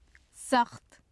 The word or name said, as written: saxt